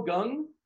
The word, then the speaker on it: Kang: I'm going with the Ho, Jung Ho Kang. I'm going there. I'm going. What? What? – It's Jung Ho Gung. – Really? It's spelled K-A-N-G like that.